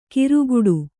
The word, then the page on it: ಕಿರುಗುಡು: kiruguḍu